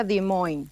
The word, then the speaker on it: Des Moines: Sí, se escribe Des Moines, pero se pronuncia Des Moines.